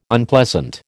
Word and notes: Unpleasant: American English

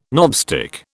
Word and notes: knobstick: British English

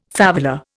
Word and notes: favela: Indian

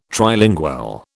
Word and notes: Trilingual: British English

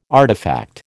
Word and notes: artifact: American English

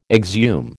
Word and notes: exhume: American English

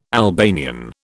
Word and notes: Albanian: British English

